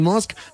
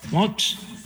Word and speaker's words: Musk: Haga de cuenta que va a decir mosca y le quita la Musk.